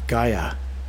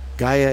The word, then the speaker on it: Gaia: It's Gaia. Gaia is spelled G-A-I-A, Gaia.